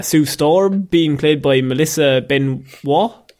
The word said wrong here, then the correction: Benoist: I think it's actually Melissa Benoist. – Benoist, okay.